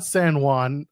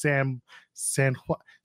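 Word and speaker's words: Samhain: Not San Juan. It's like Sam Hain, but it's pronounced San Juan.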